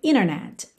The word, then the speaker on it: internet: Mas a gente já viu que existe é, uma situação no inglês americano que quando a gente tem NT, a gente pode omitir o T e ligar no N, como acontece, por exemplo, na palavra internet, que muitas pessoas pronunciam internet, internet. Ou seja, esse NT, internet, a gente tira o T e liga no N, internet, internet, ok?